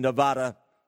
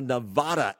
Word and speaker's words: Nevada: Now, Nevada, as I say it, that is actually the pronunciation I have read that is used by those who are not from the state, whereas those from the state prefer Nevada. So Nevada, Nevada, tomato, tomato.